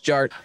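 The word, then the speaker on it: DJ Artt: And my name is Jared, aka DJ Art, with two T's for a double dose of that tink tink. The D is silent, so it's just Jart.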